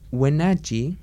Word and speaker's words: Wenatchee: para cualquier persona de la comunidad que quiera hacerse una prueba para el COVID-19, el COVID-19, en Wenatchee, así es como se pronuncia, uh el lunes 28 de septiembre de 4 a 8 pm en la dirección Café 766 South Mission Street Guanachi.